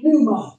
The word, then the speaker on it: pneuma: In the Greek, they use the word pneuma. Amen. It's spelled P-N-E-U-M-A.